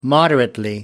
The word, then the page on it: moderately: British English